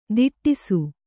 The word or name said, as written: ದಿಟ್ಟಿಸು